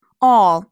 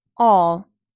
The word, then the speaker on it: all: 第一段 all，注意它发的是长音 all，并且后面有一个 L 的发音 all，舌头要卷起来。